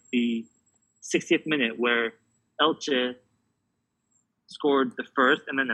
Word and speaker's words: Elche: Is it Elche? – Elche, yeah.